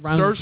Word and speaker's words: Saoirse: which is in theaters right now with Saoirse Ronan. I think that's right. It rhymes with inertia. – It rhymes with Saoirse? That's right. Saoirse Ronan. No, she told me yesterday it rhymes with inertia.